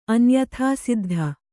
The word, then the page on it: ಅನ್ಯಥಾಸಿದ್ಧ: anyathāsiddha